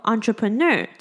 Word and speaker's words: entrepreneur: 那句子中间呢有提到 entrepreneur 这个字，这个字呢它比较特别，它拼作 entrepreneur，可是呢因为它是来自于法文，所以它的 en 呢是念作 on。它念作 entrepreneur，指的是创业家的意思。